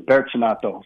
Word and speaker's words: Bertinato: The first one I want to talk about is probably obvious to you, Raul Bertinato. I don't know if I'm saying that right, Coach, but you did a good job. Technically, the Portuguese pronunciation is Bertinato.